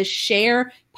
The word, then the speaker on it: Shair: Austin, Omar is the badass Costa Rican host of the Share podcast, and that is spelled S-H-A-I-R. So don't get it confused with what you might think is the spelling.